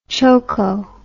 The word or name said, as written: choko